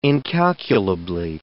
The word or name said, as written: incalculably